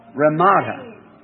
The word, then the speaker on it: Ramada: Ramada. Did I say that right? – Ramada.